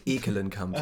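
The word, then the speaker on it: Ekkelenkamp: Neulich hat irgendjemand geschrieben, ähm, dass er ausgesprochen wird wie ähm, Ekel-Alfred, also Ekelenkamp ah, sozusagen.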